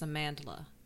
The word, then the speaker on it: Amandla: Let's get her name right first. It's Amandla. – It's Amandla. It's not Amanda. – Amandla. It might even be Amandla, but there's an L in there.